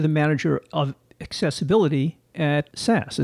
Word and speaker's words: SAS: SAS. According to their website, SAS, pronounced S-A-S-S, even though it's spelled S-A-S, once stood for Statistical Analysis System, but now the name of the company is just SAS.